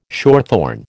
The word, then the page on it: shorthorn: American English